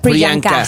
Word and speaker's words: Priyanka: Priyanka. – Priyanka.